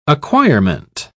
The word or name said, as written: acquirement